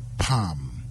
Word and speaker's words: palm: PALM. The L is silent, it's not pronounced.